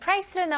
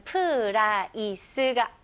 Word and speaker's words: price: 다음은 price로 넘어갈게요. price는 가격입니다. 네, 발음 연습부터 해볼게요. 네, 이거는 price가 아닙니다. 이름절로 price. price. Okay?